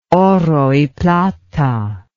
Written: oro y plata

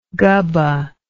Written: gubbah

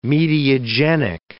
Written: mediagenic

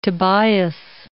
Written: Tobias

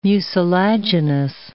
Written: mucilaginous

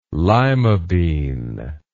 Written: lima bean

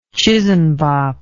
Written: chisanbop

